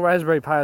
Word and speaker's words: Raspberry Pi: Yeah, the actual Raspberry Pi I was talking about is spelled Pi as in like the mathematical constant Pi. P-I with no E. But it is meant to, you know, sound like a Raspberry Pi.